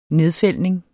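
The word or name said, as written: nedfældning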